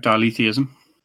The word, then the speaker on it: dialetheism: dialetheism. Is that the correct way to pronounce that? – That is correct, dialetheism.